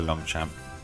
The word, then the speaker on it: Longchamp: As well, so you could, you know, get your free Longchamp clothing, and if you went to Longchamp, that's how you pronounce it.